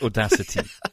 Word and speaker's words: Audacity: Does that have a T in it? – I think I did say that. If you listen to my pronunciation, which is the Queen's English, I think you'll find, Mr. Scotsman, it's Audacity.